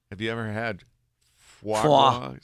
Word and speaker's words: foie gras: Foie gras? – Does get-go have foie gras? – Is that how you say it? Uh-huh. Foie gras.